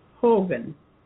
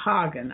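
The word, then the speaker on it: Hoggan: Hogan. Some people pronounce that Hagen. I don't know, H-O-G-G-A-N. I'm going to call it Hogan, because it's not a German name.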